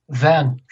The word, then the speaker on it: Van: Ron, his last name is Van Herpen, V-A-N. Am I saying that right? – Van. Van. – Okay. Yeah. Ron Van Herpen. It's V-A-N-H-E-R-P-E-N.